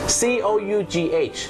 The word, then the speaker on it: cough: คุณผู้ชมครับภาษาอังกฤษเป็นภาษาที่บางครั้งเนี่ยนะครับการออกเสียงไม่แมทกับการสะกดอย่างแรงนะครับเช่นคำว่าอาการไอหรือมีการอาการไอนั่นเองครับ C O U G H ออกเสียงว่า cough cough นั่นเองน